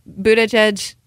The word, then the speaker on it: Buttigieg: Anyway, all in all, I mean, Pete Buttigieg, am I saying that right? – Yeah.